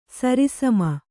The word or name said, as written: ಸರಿಸಮ